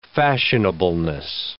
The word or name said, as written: fashionableness